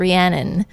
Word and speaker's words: Rhiannon: I think that some of you who are listening to this might know that my full name is Rhiannon, like the Fleetwood Mac song. But I would go into casting offices with Rhiannon, spelled R-H-I-A-N-N-O-N, written on my headshot, and I would get some really, really weird pronunciations like Rahani, Renono, Renini.